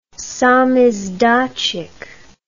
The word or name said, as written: samizdatchik